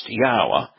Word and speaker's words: Yahweh: In the Torah, prophets, and Psalms, Yahweh included His name, writing it Yod-Heh-Wah-Heh, which is pronounced Yahweh, 7,000 times.